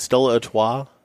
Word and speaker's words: Stella Artois: I had some, I hope I pronounced this right, Stella Artois. – Yes. – Yes.